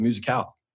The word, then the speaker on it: musicale: Bro, it's the musicale. That's how it's pronounced. Okay? That's how it's not the musical. It's High School Musical. It's not High School Musicale, but it's the spring musicale.